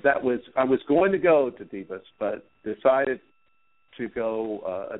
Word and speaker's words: Davos: You look at Davos, or I'm not quite sure how you pronounce the city in Switzerland when they just had their Davos.